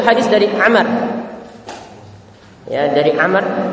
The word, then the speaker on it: Amr: Ingat kalau ada sama ain mim ra tapi di belakangnya itu ada waw, itu bacanya Amr. Tetapi kalau ain mim ra tidak ada wawnya, itu bacanya Umar. Di sini bacanya Amr.